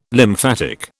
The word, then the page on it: lymphatic: British English